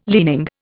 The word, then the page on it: leaning: Indian